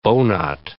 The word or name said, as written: bowknot